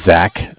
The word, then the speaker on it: Zach: My name is Zach, spelled Z-A-C-H. – Uh-huh. – And I'm calling from Bank of Cardiff. Cardiff is spelled C-A-R, like car.